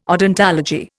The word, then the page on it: odontology: Indian